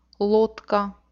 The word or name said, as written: lodka